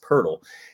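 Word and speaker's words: Poeltl: So somebody invented an NBA player guessing game called Purtle after Jakob Purtle, even though it's not spelled the same, it is pronounced Purtle.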